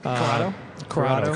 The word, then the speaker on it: Corrado: I mean, I like the Volkswagen Coronado. – Yeah. – I think that's the way you pronounced it. – Corrado, excuse me.